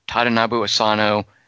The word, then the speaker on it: Tadanobu Asano: It's one of the few Japanese names that I feel comfortable saying. Akira Kurosawa, Tadanobu Asano. – You didn't sound comfortable. – You did say it correctly.